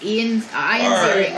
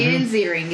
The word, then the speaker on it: Ian Ziering: His name is Ian Ziering. – Okay, all I know is Guy from 90210. – Ian Ziering is his name.